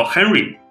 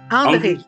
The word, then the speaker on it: Henry: 英语叫 Henry，结果到法语拼写差不多，但是念起来叫 Henry。